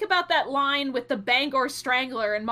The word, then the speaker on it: Bangor: I don't know whether they just wanted to name drop it or because the proper pronunciation is Bangor. They did it pretty well. Like people that don't know call it banger and that's completely wrong.